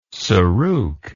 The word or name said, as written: saruk